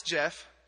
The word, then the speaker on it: Geoff: My name is Geoff. She goes, how do you spell it? And I said, G? She said, G. So it's Geoff. I said, no, it's Geoff. She said, don't make, just, what's next? What's next? G-E-E-O.